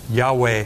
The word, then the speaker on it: Yahweh: Yahweh is how the Jews would pronounce it.